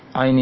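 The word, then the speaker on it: Ain (عين): Ain itu berada di tengah tenggorokan, dimulai dari tengah pertengahan tenggorokan, kemudian dialir, dijalankan suaranya. Ain, ain, ain. Jangan sampai ain seperti ada hamzah nantinya.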